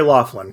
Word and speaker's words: Loughlin: But the romantic lead is how do you say her name? – It's Lori Loughlin, correct? – Lori Loughlin.